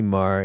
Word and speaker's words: Meagher: And you are Nancy Maher, and you spell it Meagher.